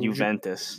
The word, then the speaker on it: Juventus: Juventus.